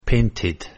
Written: painted

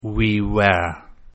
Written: we were

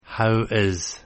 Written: how is

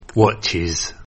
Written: Watches

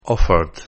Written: offered

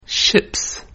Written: Ships

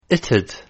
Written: it'd

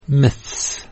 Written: Myths

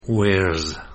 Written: Wears

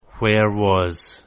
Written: where was

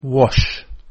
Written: wash

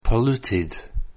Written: polluted

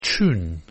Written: tune

